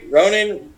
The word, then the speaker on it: Ronan: Did I say it right? Ronan? – Ronan, yeah. – Ronin. Okay, I was saying, I said Ronin.